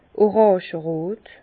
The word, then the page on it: oràngerot: Bas-Rhin; Herrlisheim